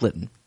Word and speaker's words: Lytton: I think it's Lytton.